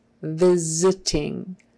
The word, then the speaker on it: visiting: That S turns into a Z. It's not visiting. Visiting. It's visiting. Probably the reason why the S is pronounced like a Z is because that first sound, V, is a voiced sound.